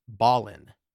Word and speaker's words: Belen: and the name of the town that I mispronounced is spelled B-E-L-E-N. I pronounced it Belen, and I believe it's Balin, right? – There you Balin.